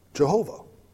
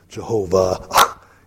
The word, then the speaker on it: Jehovah: If the W is pronounced like a V, you get Jehovah.